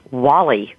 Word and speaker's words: Walle: I use Walle. It is like the movie, and the thing I loved about it is it's spelled just like the little Pixar movie, W-A-L-L-E, and it is my name, and it's what I came into this world as, so I go by Walle.